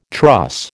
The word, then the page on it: truss: American English